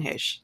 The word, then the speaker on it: Heche: I've heard a lot of pronunciations, but my mom is like hella into Hollywood, so it's Anne Heche.